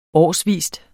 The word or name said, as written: årsvis